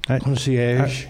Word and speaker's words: concierge: Well, the first thing is I got a luxury card concierge. Is that how you pronounce that word? – Concierge.